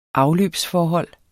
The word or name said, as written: afløbsforhold